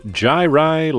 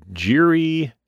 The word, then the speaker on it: Jirai: The title card for this week's episode is Jirai Labyrinth, the very often mispronounced card. We see Jiri. It's none of that is correct.